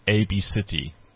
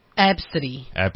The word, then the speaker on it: Abcde: So A B C D E Redford is the kid's name, but it's pronounced A B City. – Absidy. Yeah. – Okay. – So it's a bit of a trend in the U.S. now. – Absidy, spelt A B C D E.